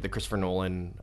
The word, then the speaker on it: Christopher Nolan: You can really tell that they tried to take what the Christopher Nolan Batman movies we're doing.